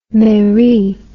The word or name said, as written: mairie